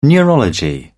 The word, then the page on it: neurology: British English